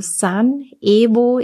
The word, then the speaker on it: Sun Evo: Also Sun Evo, E-V-O News auf Telegram.